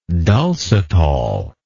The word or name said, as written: dulcitol